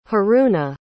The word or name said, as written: haruna